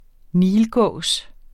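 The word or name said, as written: NILGÅS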